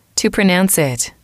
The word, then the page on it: to pronounce it: female